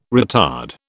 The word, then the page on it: retard: British English